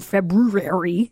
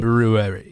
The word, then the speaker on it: February: Because you have to go February, if you want to say it and really pronounce it correctly. – February.